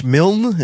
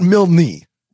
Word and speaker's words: Milne: M-I-L-N-E. – No, I mean, it's Milne. You, A.A. Milne. – No, it's definitely not Milne. It's a one-syllable last name.